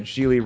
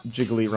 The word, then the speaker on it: Gigli: That's right, Gigli rhymes with really, not Jiggly rhymes with Piggly Wiggly.